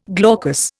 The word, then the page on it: glaucous: Indian